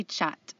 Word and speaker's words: chaat: oh, and that Indian dish is called papri chaat.